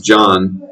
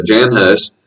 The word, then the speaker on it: Jan: That's a man, not, it's pronounced John usually, but Jan Hus.